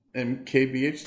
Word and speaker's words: MKBHD: MKBHD. Did I say that right? – MKBHD.